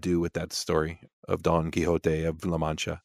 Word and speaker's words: Don Quixote: There is a donkey in the movie, Sancho Panza, Don Quixote. – Don Quixote. – I guess I should pronounce it properly, Don Quixote.